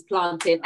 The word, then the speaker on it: plantain: Now the Ghanaians pronounce it plantain, but because I'm Caribbean, I say it the correct way, which is plantain.